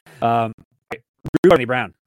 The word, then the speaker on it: Randy Brown: You called him Rudy. Like, we actually messed up for once. – I did. In my co-host was a good pronunciation. – He was. Your co-host did great. Randy Brown. Not Rudy.